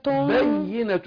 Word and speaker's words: بينة: قد جاءتكم بينة بينة، الياء مشددة بتاعت بينة لازم تختلف عن الياء اللي غير مشدده في النطق، فقول ايه قد جاءتكم بينة بينة، شوف التشديد الياء، لكن بخلاف لو قلت بينة من غير تشديد، بينة بينة.